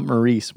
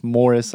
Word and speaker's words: Morris: Not Maurice, Morris, Iowa. Even though it's spelled like Maurice, and had a few hundred guys combined at these conferences.